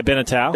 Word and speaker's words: Beneteau: Beneteau? – Yeah, Beneteau.